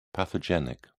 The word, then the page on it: pathogenic: British English